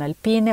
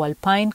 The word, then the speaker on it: Alpine: Pierre Gasly en el noveno con Alpine o Alpine, como ustedes prefieran pronunciarlo, y cerrando el grupo de los diez primeros, Alexander Albon con un Williams.